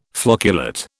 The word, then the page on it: flocculate: British English